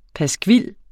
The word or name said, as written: PASKVILS